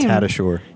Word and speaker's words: Tadashor: Dave, did I say that right? Sorry. I don't know. How do I say your name? – Tadashor. – Tadashor. That's hard.